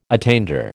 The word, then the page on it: attainder: American English